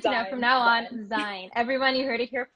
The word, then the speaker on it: XYNE: It came out of nowhere, but it's pronounced Zine.